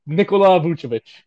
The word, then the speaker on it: Nikola Vucevic: I mean, that guy Nikolai Vucevic, how do you pronounce his name? – Nikola Vucevic.